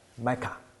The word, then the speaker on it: Micah: 所以如果用希伯来文念的话，他就是念成说，神啊 Micah，他的名字，他念他自己的名，因为 Micah 这个字就是有何神像你这个字。